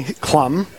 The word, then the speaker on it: Klamm: It's actually Klamm. – Klamm. Oh, we were wrong. Plum. It rhymes with plum.